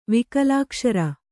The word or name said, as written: ವಿಕಲಾಕ್ಷರ